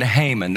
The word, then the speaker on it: Haman: Excuse me, and introducing a new character in the story, a man named Haman. That's how I'm going to pronounce his name. Other people pronounce it differently, but other people have the freedom to be wrong if they want.